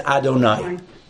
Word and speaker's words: Adonai: It uses this name, and it tells us that you don't pronounce it the way it's written. We pronounce it as Adonai.